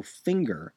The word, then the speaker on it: Finger: Now, the reason I'm saying Bill Finger and not Bill Finger is because according to Don Markstein's Toonopedia, the name Finger is pronounced to rhyme like zinger, not linger.